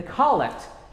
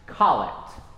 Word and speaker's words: collect: The collect is the prayer that we use at the opening of worship, and it is pronounced collect, not collect.